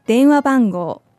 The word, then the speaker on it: 電話番号: 電話番号電話番号電話番号 This is a little bit of a long word, so let's break down the pronunciation for you.